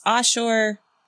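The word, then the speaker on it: Ashur: So it's Asshur. It can just be spelled like that, or you can see the S with the little carrot on the top of it. It's got little bunny ears, and that letter is a shen.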